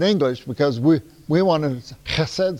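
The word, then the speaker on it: chesed (חסד): and this is how you say it in Hebrew, it's called chesed. And sometimes a real Hebrew will pronounce it chesed, okay? So you'll see it written in English, C-H-E-S-E-D. Sometimes you'll run across it in your Bible books, H-E-S-E-D, chesed.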